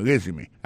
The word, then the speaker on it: résumé: I see from your resume, moi, ouais, nan CV ou, nan curriculum vitae ou, gade ki jen yo prononce mon résumé, asi fom yon résumé, accent tombé sur premier syllabe là.